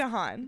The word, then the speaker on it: DeHaan: It's DeHaan.